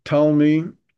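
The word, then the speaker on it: Ptolemy: The story is that Talmi, with a silent P at the beginning, Talmi gathered 70 Jewish scholars,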